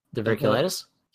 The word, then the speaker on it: diverticulitis: Diverticulitis? – Yes.